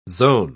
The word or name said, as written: zone